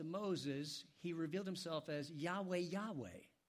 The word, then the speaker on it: Yahweh: And so sometimes in some of the songs we sing, you'll see the word Yahweh with a Y-A-H-W-E-H. That's probably the most correct pronunciation that we can arrive at today.